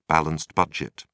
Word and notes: balanced budget: British English

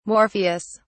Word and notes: morpheus: British English

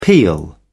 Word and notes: peal: British English

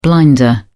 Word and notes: blinder: British English

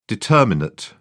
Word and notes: determinate: British English

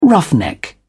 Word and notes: roughneck: British English

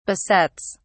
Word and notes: besets: British English

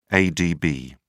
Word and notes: adb: British English

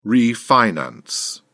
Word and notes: refinance: British English

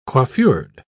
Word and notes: coiffured: British English

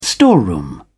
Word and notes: storeroom: British English

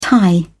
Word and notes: tie: British English

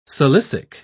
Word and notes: silicic: British English

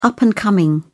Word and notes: up-and-coming: British English

